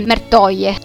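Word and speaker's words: Merteuil: Nel corso di questa sera vi ho parlato della marchesa di Umartei, ma in realtà la pronuncia corretta francese è Mertoie,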